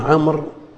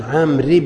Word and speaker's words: عمرو: قال وعن عمرو بن شعيب، بعض طلاب العلم ينطقون عمرو بالواو والضم ومدري ايش، هذا الكلام ما هو صحيح هنا. وعن عمرو بن، والواو هذه يؤتى بها للتفريق بين عمرو وعمر، وإلا تأتي عليه الحركات كلها. اسمعوا، يتحدث عن شخص عمرو، ما هو صحيح، ما هو عمرو وعمر. رأيت عمرا، مررت بعمر، فالواو إنما لا تنطق، إنما يؤتى بها للتفريق بينه وبين عمر.